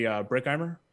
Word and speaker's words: Bruckheimer: I guess it's like that guy we just mentioned, Jerry Bruckheimer.